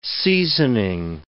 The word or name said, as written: seasoning